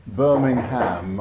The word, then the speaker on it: Birmingham: It's terrific to be back in Birmingham, I should say, that's the right way to say it, with Eileen, my wife, and tonight and tomorrow.